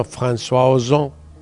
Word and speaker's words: François Ozon: No, muy pronunciado. – Bueno, François Ozon, es así.